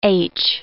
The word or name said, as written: h